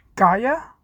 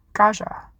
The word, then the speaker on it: Gaja: And when the Kidemonas calls Lysander the G-A-H-J-A word, TGR pronounced it Gaia, with like a soft J. But as the Red Rising wiki points out, the word is derived from the Japanese term for outsider, Gaijin. So let this be the only time I praise Lysander and his narrator from Iron Gold for setting the standard for what I think would be the proper way to say Gaja.